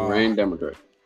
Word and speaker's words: Moiraine Damodred: We got um, I don't know if I pronounced this correct, but Moiraine Damodred, right? – Uh, Moiraine Damodred.